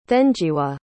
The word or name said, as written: thenjiwe